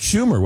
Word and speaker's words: Schumer: I believe that was Chuck Schumer, was it not? – Yes. – Yeah. – The senator's name rhymes with rumor.